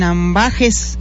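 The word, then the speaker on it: ambages: Así que llámenos al teléfono y platiquemos sobre la muerte sin ambajes. – Sí, ¿lo dije bien? Sí, lo dijiste bien.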